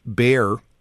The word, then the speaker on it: Bayer: And the product that you will find at the garden store is Bayer, B-A-Y-E-R, the people that make the aspirin, or Bayer, some people would pronounce it.